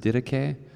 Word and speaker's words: didache: And the word for doctrine in verse 42 is didache, and some pronounce it didache, which means that which is taught.